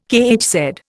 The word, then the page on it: kHz: Indian